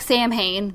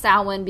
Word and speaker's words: Samhain: And then the Gaelic people had Samhain, which looks like Samhain, because I always read it as Samhain in my head, but it's actually pronounced Samhain because the M is silent.